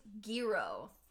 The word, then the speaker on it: Gyro: His name's Giro. – That's what it was, Giro. – But it's spelled like gyro, like Euro.